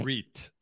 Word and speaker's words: REIT: A REIT. And a REIT is spelled R-E-I-T.